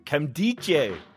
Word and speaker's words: Nkemdiche: And the N is silent, Hodge. How dare you mispronounce it? I even said it earlier for you. Kemdiche.